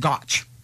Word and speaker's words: gotch: So ginch, gaunch, gitch, gotch, and they all have to be pronounced with a hard G.